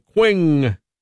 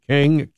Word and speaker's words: Qing: the Qing dynasty. Probably don't pronounce it that way. King, Q-I-N-G.